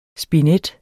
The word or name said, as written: spinet